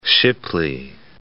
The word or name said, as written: Shipley